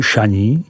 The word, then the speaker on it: Chagny: Potoblast, která je ohraničena vesničkou Chagny, vyslovuj Šaní, nabízí nějakých 25 kilometrů k obci Saint-Valérien, vyslovuj Saint-Verlá, která tvoří hranici mezi Côtes Chalonnaises a následující potoblastí Mâconnais, vyslovuj Mâconnais.